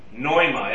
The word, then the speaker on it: pneuma: We would drop the, we would make the P silent in our, in English. Pneuma, as in pneumatic or pneumonia. P-N-E-U-M-A. Okay? The Greeks pronounce it. They don't have any silent letters. So they would actually pronounce it pneuma.